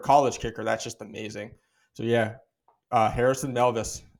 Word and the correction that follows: Mevis: Mevis, I think is how you pronounce his last name.